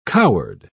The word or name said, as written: coward